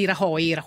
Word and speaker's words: Rajoy: Senti, invece, molto brevemente, sai, in Spagna ha vinto la destra di Rajoy, si pronuncia così, sì.